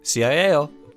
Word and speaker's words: ciao: CIAO. – Ciao. – Oh, I've only ever seen it written down. – Okay. – It's pronounced ciao? That's crazy.